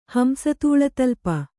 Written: ಹಂಸತೂಳತಲ್ಪ

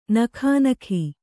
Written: ನಗ್ನಿಕೆ

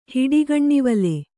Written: ಹಿಡಿಗಣ್ಣಿವಲೆ

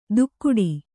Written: ದುಕ್ಕುಡಿ

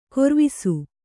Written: ಗರುವಿಸು

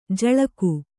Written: ಝುಳಕು

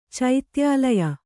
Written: ಚೈತ್ಯಾಲಯ

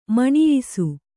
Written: ಮುನಿಯಿಸು

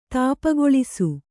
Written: ತಾಪಗೊಳಿಸು

